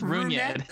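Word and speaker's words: Rougned: Ninth, Rodnett Odor at second base. I can't believe I pronounced that correctly. Rotation as follows. – You didn't. – It's Runyed.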